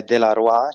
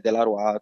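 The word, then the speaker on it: Delaroix: Allora, io potete seguirmi sia su Instagram con uh, il nome Delaroy, che si scrive de-la-roix, um, ehm, o su TikTok scrivendo sempre Delaroy tutto attaccato.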